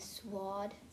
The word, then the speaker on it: sword: Yeah, I need the sword. – Sword. – Sword. Who cares? – The W is silent, but it's very cute the way you say it.